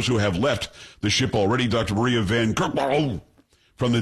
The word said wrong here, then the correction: Van Kerkhove: It's Van Kerkhove. Kerkhove. I'm pretty sure that's how you pronounce it.